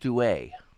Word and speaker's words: Duhé: It's Wethan, and it's pronounced Douay. Ellie Douay. – Oh, I didn't know she was French. – Ellie Douay. It's got the little accent above the E.